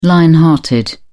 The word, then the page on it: lion hearted: British English